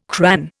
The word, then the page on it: cran: Indian